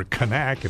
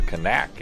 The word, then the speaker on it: Knack: It's the Knack, or Knack if you put the K in there. And the Knack, eh? – Yeah, uh-huh. Knack with the K is silent.